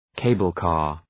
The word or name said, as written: cable car